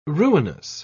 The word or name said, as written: ruinous